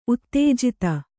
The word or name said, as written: ಉತ್ತೇಜಿತ